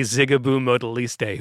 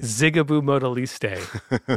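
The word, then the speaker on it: Zigaboo Modeliste: But it's definitely Zigaboo Modaliste, who's my favorite in my top three drummers. – I just like the fact you pronounced that correctly. – Zigaboo Modaliste.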